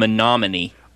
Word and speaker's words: Menominee: Gojibik, Iron, Dickinson, and Menominee.